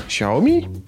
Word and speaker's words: Xiaomi: No to proszę bardzo, Xiaomi. Pisze się Xiaomi, jak kto woli. – Jak się czyta, jeszcze raz? – Xiaomi? – Ale pięknie to mówisz. Pięknie, prawda? Starałem się.